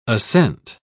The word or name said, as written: assent